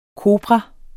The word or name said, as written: kopra